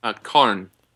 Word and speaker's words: Karn: Karn. – How do you spell that? – K-A-R-N.